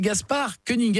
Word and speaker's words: Gaspard Koenig: Il s'appelle Gaspard Koenig. Est-ce que je prononce bien ? – Bonjour, oui.